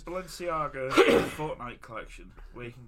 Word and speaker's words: Balenciaga: Oh, Balenciaga? – Ah, it's a Balenciaga.